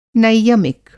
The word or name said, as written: नैयमिक